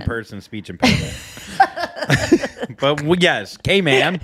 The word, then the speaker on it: Cayman: Cayman. – Yes. Yes. – Okay, man. – Okay, man. – That's how I learned how to say it. So it's not Cayman, it's Cayman.